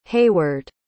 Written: hayward

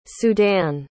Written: sudan

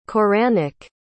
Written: koranic

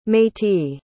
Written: métis

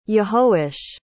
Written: yehoash

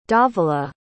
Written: dhavala

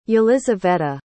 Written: elizaveta

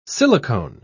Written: silicone

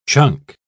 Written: chunk

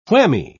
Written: whammy

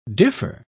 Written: differ

